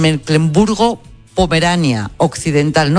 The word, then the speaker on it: Mecklemburgo-Pomerania Occidental: Mecklemburgo-Pomerania Occidental.